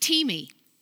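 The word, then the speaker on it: timi (τιμή): The Greek word for honor is T, it's pronounced, it's actually spelled like time, T I M E, but it's pronounced, pronounced timi.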